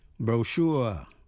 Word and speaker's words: brochure: Tiếp sau đây chúng ta học thêm 9 từ mà trong đó CH phát âm là SH. Brochure, brochure, B-R-O-C-H-U-R-E, brochure là cuốn sách mỏng. Cash, cash, cash, C-A-C-H-E là kho vũ khí hay là nơi cất giấu.